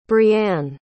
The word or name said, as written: breanne